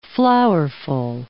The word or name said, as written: flowerful